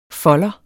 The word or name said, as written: folder